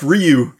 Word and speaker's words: Ryu: It is pronounced Ryu.